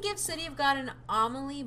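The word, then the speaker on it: Amélie: Emile? I don't know how to pronounce Amelie? – This one. It's like Amelie, yeah.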